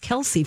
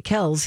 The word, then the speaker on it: Kelce: and they asked him why um, he had them thinking it was pronounced Kelsey for the first 25 years of their lives. And it turns out Ed got tired of correcting people who pronounced it Kelsey. So when the boys asked him if they should go back to Kells, he said, Do whatever you want.